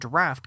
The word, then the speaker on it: draught: This is how the term draft came into being. The original spelling of draft was D R A U G H T, but was generally still pronounced as draft.